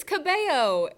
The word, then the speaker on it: Cabello: It's Cabello? It's Cabello. It's Spanish.